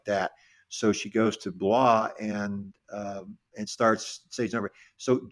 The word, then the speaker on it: Blois: Blois,